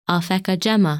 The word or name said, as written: Alphecca Gemma